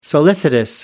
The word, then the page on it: solicitous: American English